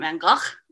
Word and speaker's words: Gogh: I used the correct Dutch pronunciation of Vincent van Gogh's last name, which is Vincent van Gogh, which is not endearing, as I said, to 7-year-olds, because that's when I was saying it.